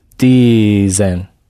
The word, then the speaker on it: dizem: And what happens to the Z when it has a vowel after it? – Dizem.